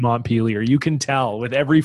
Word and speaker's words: Montpelier: the capital of Vermont is pronounced Montpelier. – Yeah. – You know, I think you probably think it is like, it's, I mean, come on, it's named after Montpellier.